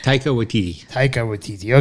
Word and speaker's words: Taika Waititi: The directors and writers were Jermaine Clement and Taiki Waititi. – Taika Waititi.